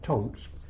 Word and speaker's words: Tank: We arrived at a place called Tonks. It's spelt Tank, but they pronounced it Tonk.